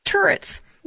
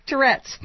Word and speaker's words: Tourette's: Turrets. – No! – I know, I said it wrong on purpose. It's Tourette's. – Yes. – Okay. Oh, you had the emphasis on the wrong syllable.